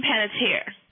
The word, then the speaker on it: Panettiere: How do you pronounce her last name? – Yeah, Hero. Hayden Panettiere.